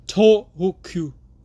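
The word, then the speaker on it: Tōhoku: Tohoku.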